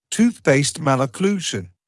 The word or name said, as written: tooth-based malocclusion